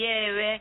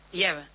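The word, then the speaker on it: Ebb: Kander y Eve. – No, Eve, ay, siempre lo digo mal.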